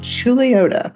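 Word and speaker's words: Chuluota: And they live in a small town, and it's pronounced Chuliota.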